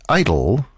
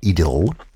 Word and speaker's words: idyll: An idyll, or if you prefer the English pronunciation, an idyll, in other words,